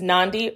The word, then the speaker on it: Nandi: My name is Nandi, rhymes with Gandhi.